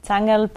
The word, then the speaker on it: Zangerl: A little bit different. Okay. Normal, it's Zangerl, but it's impossible Zangerl. To say it.